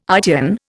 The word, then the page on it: ITN: Indian